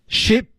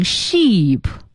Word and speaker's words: ship: Ship to Kenya. – Ship to Kenya. – Ship to Kenya. – Ship to Kenya. – Ship to Kenya. – Ship to Kenya. – 嗯，千万不要读成 sheep，错了啊。 – 嗯。 – Ship to Kenya, sheep 那是绵羊啊，sheep。